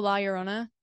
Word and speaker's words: La Llorona: Oh, La Llorona.